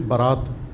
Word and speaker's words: برات: استعمال ہوتے ہوتے براءت سے برات بن گیا ہے، اس کا حمزہ غائب ہو گیا ہے اور الف کے ساتھ تلفظ ہونے لگا ہے. فارسی اور اردو میں برات کہتے ہیں اس کو ہم.